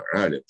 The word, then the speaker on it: Алим: Ну, например, есть такое имя Алим, да? Вот, с ударением на А, Алим, что означает знающий.